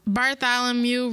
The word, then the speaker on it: Bartholomew: Bartholomew Roberts. Is that how you say it? – Yeah.